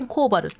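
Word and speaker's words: 川原: 川とか川に原っぱの原って書いてコーバルって読むんですけどこのコーバルという地域に 100 世帯以上住んでいた。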